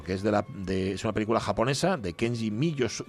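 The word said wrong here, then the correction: Mizoguchi: Miyo, Misoguchi, Kenji, Sube la música, por favor.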